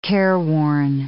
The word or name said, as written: careworn